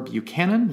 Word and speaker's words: Buchanan: Hit me up on Twitter or Instagram, Adam underscore Buchanan.